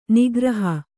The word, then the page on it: ನೌಕಾರೋಹಿ: naukārōhi